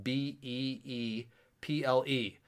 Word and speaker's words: Beeple: but it's spelled B E E P L E, like people, but it's Beeple.